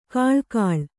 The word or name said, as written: ಕಳೆಕೀಳು